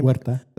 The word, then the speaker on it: Huerta: Huerta.